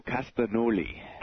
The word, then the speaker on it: Castagnoli: Claudio Castagnoli.